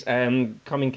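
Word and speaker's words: Caoimhín: Coming Kelleher with... or Queeve Kelleher, I think is how you pronounce it.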